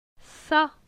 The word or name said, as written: Ca